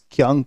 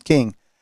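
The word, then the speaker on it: Kueng: This is a kind of an interesting little profile of Alex King, is how it's pronounced. It looks like it's Kyung, but it's pronounced King.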